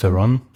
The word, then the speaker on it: Theron: Wie spricht man das richtig aus? – Ja, passt. – Theron.